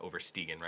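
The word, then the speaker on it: Oversteegen: I'm going to go with a hard G on that, Overstegen, right? – That's good. I mean, I don't speak Dutch. I speak German, but generally that's correct.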